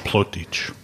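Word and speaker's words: Plautdietsch: The film is in Plautdutch. Did I say that right? – Plautdutch. – Okay, thank you.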